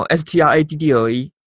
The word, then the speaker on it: straddle: 好，再来再来提到刚刚说到跨式跨式策略的话，在英文里英文里面可以叫 straddle 或 struggle，啊，这可能我发音也不是非常的准，嗯，可能也听不太清楚，straddle s t r a d d l e。